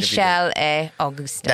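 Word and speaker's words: Michel et Augustin: But what's the French? How would you say it? – Michelle et Augustine.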